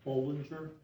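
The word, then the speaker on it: Bohlinger: Bollinger, B as in boy, O-H-L, I-N-G-E-R.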